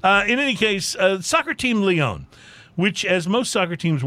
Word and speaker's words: Lyon: So the famed French soccer team is called Lyon, or Lyon, I think is the correct pronunciation. Lyon. But it's spelled like Lyon, L-Y-O-N.